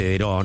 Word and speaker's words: Daireaux: Daireaux se escribe, se dice Deró, ¿no? Obviamente en castellano.